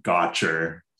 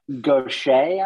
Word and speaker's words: Gaucher: So I would never pronounce it Gotcher. – No. – So how would I pronounce it? – You don't want to participate in Gotcher journalism. That's right. Gaucher, I would guess.